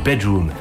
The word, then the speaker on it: BDRMM: BDRMM, prononcé Bedroom, soit chambre en français.